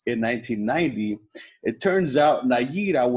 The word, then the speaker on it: Nayirah: Nayira? N-A-Y-I-R-A-H.